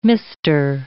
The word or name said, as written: mister